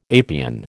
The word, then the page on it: apian: American English